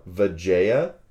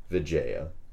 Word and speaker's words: Vijaya: People in the chat are asking you to maybe slow down your pronunciation of that type of rocket. – Oh, Vajaya? – Interesting. – V-I-J-A-Y-A rockets. – Yeah. – Vajaya.